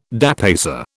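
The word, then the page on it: dépaysé: British English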